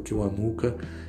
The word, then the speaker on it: Kiwanuka: Vocês podem procurar qualquer coisa do Michael Kiwanuka, é K-I-W-A-N-U-K-A, Michael Kiwanuka, quase como se pronuncia mesmo, que acho que vocês não vão perder tempo é, vendo, não.